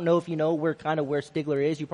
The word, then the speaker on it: Stigler: something a little bit about the congregation which the Lord has blessed me and my family is, it's pronounced Stigler, Oklahoma, very metropolis city, about 3,000 people.